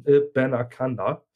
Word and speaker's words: Abanikanda: Um was Kurzes zu ihm zu sagen, Israel Abanakanda, also falls ihr den Namen lest, wird er geschrieben Abanikanda, er wird aber ausgesprochen Abanakanda.